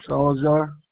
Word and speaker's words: Salazar: Joe Salazar. – Spell that last name for me. – S-A-L-A-Z-A-R.